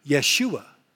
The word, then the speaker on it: Yeshua: Because in the Hebrew, Joshua is pronounced, get this, Yeshua, Yeshua. Does that sound familiar to anybody? That is actually Jesus in Hebrew, Yeshua.